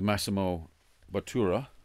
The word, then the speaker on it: Massimo Bottura: You published 3 books with Massimo Bottura.